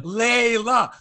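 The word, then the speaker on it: Leylah: the commercial, for everyone to hear, I'm Leila Fernandez.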